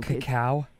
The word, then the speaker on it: cacao: How do you pronounce that? – I don't know. – Cacao? – It's probably just cocoa, right? But it's spelled with an A-O instead of an O-A.